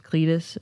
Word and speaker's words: Cletus: Cletus, is that right? – Yes.